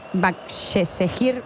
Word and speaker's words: Başakşehir: Başakşehir, sí, ho dius bé.